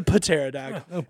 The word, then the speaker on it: pterodactyl: Pterodactyl spelled with a P. It's a pterodactyl.